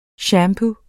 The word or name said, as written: shampoo